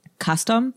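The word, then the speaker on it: kastom: Just also to say Castan is, is again like a local pronunciation of custom,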